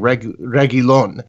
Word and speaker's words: Reguilón: You were making some interesting points, I think, before the game when the lineup came out, that it was a 3-5-2 or a 5-3-2 a lineup with Carvajal and Reguilón. Sorry, Reguilón. I've actually Reguilón. Found out recently that I was pronouncing that wrong. – Well, like a million people always thought there was an extra I in there after the L.